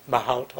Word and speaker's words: Mahout: So another one of these open source sort of machine learning environments is something called Mahout, I think, or is that correct pronunciation? Maybe you know, for it's Mahout also.